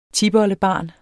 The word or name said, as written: tipoldebarn